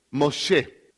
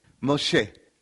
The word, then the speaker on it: Moshe: Moshe. It's not Moses. Moshe.